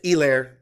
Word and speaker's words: Helaire: That's right, it's Helaire, not Clyde Edwards-Helaire. It's no H, the H is silent.